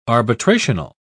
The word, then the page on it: arbitrational: British English